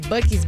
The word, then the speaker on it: Buc-ee's: Buc-ee's. – To use the bathroom? – So you, how did you know? – Because those Buc-ee's bathrooms, nothing compares.